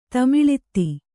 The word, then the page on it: ತಮಿಳಿತ್ತಿ: tamiḷitti